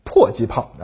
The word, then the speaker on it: 迫击炮: 这两个特点呢决定了这种火炮可以在距离目标较近的地方发射，炮弹呢可以越过各种障碍物落到敌人的头上，这就是它为什么被叫做迫击炮了啊，就是啊迫近敌人的地方攻击它的意思。只不过呢后来约定俗成，大家把这个词儿啊读成了迫击炮，成了一个专有名词，而我国台湾地区呢至今仍将它读成。迫击炮啊，并且进一步简化成了破炮啊。